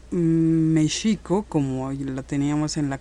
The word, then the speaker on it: México: Ya México, aunque se escribe con X, pero se pronuncia con J, con la J española.